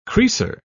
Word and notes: creaser: British English

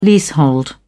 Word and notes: leasehold: British English

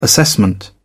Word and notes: assessment: British English

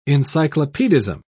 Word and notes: encyclopedism: British English

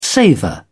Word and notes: savour: British English

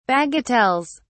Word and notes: bagatelles: British English

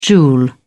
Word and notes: joule: British English

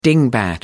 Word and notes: dingbat: American English

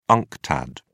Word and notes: unctad: British English

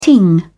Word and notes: ting: British English

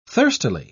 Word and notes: thirstily: British English

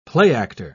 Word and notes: playactor: British English

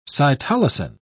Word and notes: cytolysin: British English

